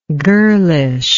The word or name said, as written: girlishness